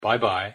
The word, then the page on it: bye-bye: English